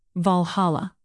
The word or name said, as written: valhalla